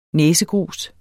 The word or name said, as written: næsegrus